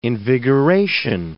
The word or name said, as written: invigoration